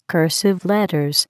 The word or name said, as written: cursive letters